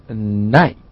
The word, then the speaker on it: knight: Next we have the word night， night， K-N-I-G-H-T， night，下一个单词大家如果只是通过发音来判断的话呢，可能跟我们学过的这个 day and night，夜晚呢是发音基本是一样的，但是呢它的拼写前面有一个 K，它表示说在中世纪的骑士叫 night。